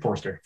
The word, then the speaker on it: Forster: So Robert Foster. – Forster. – That's how you say it.